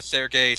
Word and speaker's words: Serge: Sergei slash Serge, however you want to pronounce it.